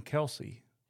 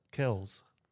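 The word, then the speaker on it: Kelce: You do not pronounce their name Kelsey. Travis Kelsey, Jason Kelsey, that's not their name. It's Kells.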